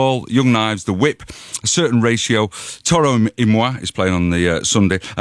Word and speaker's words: Toro y Moi: Toro y Moi, it's spelled T-O-R-O-Y, just the letter Y, M-O-I. As in moi.